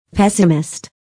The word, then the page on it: pessimist: pésəmist; ペサミスト